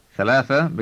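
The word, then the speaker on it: ثلاثة: With the numbers 3 through 10, the A sound at the end of the word drops out before whatever is being counted. So before a word like dollar, for example, thalatha becomes thalath, and khamsa becomes khams.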